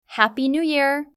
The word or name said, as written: happy new year